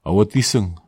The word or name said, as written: awotiseg